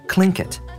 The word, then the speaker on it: Tlingit: The students are learning a language called Tlingit, commonly pronounced as Tlingit in English.